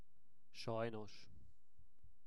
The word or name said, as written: sajnos